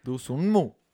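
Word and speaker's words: Dosunmu: Así es que se pronuncia. Ayo de Nu. – Ayo. De numu. De Sunu. No, Dusu. Dusunmu. – Dusunmu.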